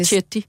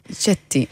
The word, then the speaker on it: Setti: Ja, altså på italiensk, så tror jeg nok, det udtales Chetti. – Chetti, okay.